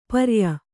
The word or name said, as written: ಪರ್ಯ